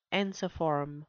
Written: ensiform

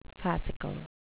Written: fascicle

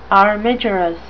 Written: armigerous

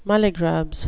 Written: mulligrubs

